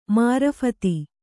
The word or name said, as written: ಮಾರೀಫತ